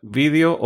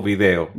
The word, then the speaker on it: vídeo: Y tú pronuncias la palabra vídeo o video. – Vídeo. – O sea que eso todavía lo manejas como en España.